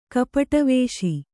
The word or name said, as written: ಕಪಟವೇಷ